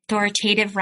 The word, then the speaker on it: authoritative: And authoritative rhymes with give.